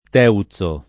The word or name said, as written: Teuzo